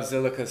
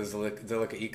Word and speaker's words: Zilliqa: But yeah, no, I think it's interesting too about Zilliqa space. I've been pronouncing it wrong forever. It's Zilliqa, not Zilliqa.